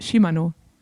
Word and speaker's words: Shimano: Jetzt habe ich ja aber eine japanische Teamkollegin gehabt und Shimano ist ja japanisch und dann habe ich sie mal gefragt, wie spricht man das eigentlich aus und sie hat bestätigt, es ist Shimano. Also ja. Es ist tatsächlich richtig.